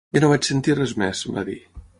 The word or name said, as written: vaig